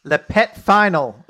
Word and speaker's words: pet final: Le pet final is apparently how you say it.